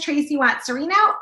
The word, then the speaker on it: Tracey Watts Cirino: My name is Tracey Watt Cirino, and that is spelled T R A C E Y W A T T S C I R I N O.